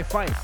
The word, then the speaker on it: Pfeif: And I was like, well, my name is DJ Pfeif. And they were like, DJ Five? And I was like, nah, it's DJ Pfeif.